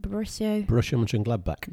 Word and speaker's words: Borussia Mönchengladbach: How do you pronounce that? – Borussia Mönchengladbach. – Wow, that's smooth as you like.